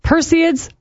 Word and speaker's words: Perseids: The Perseids, which I've never said that correctly, apparently, just found out how to pronounce it. It's a meteor shower. Perseids. I did it again. Perseids. That's how you do it. Perseids meteor shower happening on Wednesday.